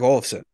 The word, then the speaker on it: Olofsson: acquiring Fredrik Olofsson. Did I say that right? Did I get his name right? – You did.